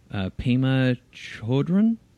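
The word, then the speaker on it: Pema Chödrön: Pema Chodron? – Mm-hmm. – Did I say it right? – Yep, I think so.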